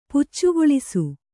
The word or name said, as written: ಪುಚ್ಚುಗೊಳಿಸು